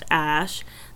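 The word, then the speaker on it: Æ: Okay, so Grimes' reasoning for the name was X is just the letter X, then A-E is pronounced Ash, then A-12 is, meaning it's the Archangel 12, the predecessor to the SR-71, which is the coolest plane ever.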